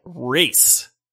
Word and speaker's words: Rayze: And this is a game called Raze, R-A-Y-Z-E, but they say it like race.